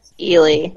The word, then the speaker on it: Ely: First off, do we pronounce it Ely Callaway or Eli Callaway? – Ely.